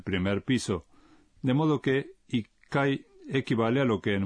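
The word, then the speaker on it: Ikai (一階): Uno es ichi, de modo que será ichikai. – No, la respuesta correcta es... – Ikai. – Ichikai es un poco difícil de pronunciar, por eso se dice...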